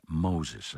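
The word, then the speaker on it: Moses: τον Εβραίο φιλόσοφο Μόζες, όπως προφέρεται από τους Σάξονες, Μέντελσον.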